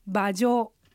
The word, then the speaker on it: 马上: 馬上は漢字で書くと馬の上、馬上と書きます。馬はアクセントの練習出てくるあの馬の三声です。下がって上がります。上は四声、上から下がります。三声四声の組み合わせです。